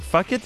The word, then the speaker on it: Phuket: Oh, you call it fuck it? Is that how you pronounce it? – I mean, phone. – Yeah, that is kind of how you should pronounce it, right? That's about right. Actually, you're spot on.